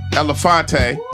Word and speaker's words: Elefante: Cutting, Elefante, rhymes with Belafonte.